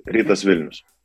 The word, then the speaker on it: Rytas Vilnius: Now, your rightness, Vilnius, is I pronounce right? Rightness, Vilnius. – Rytas Vilnius. – I'm not good in language, so Rytas Vilnius, assistant coach.